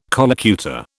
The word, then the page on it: collocutor: British English